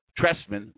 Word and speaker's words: Trestman: Trestman, remember, silent T, his departure.